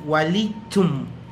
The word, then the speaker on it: walichum: Y en este caso, si es walichum, como pronunciamos nosotros, chum significa que es mujer.